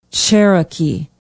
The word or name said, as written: cherokee